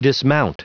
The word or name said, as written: dismount